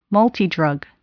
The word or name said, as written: multidrug